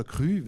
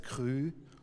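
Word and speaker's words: Cru: Aber Krü, äh, oder Krü wird es ja ausgesprochen, mein Bruder, der ist Weintrinker und der dachte, ich bin nicht ganz knusper, weil ich Krü gesagt hatte. Das heißt Krü.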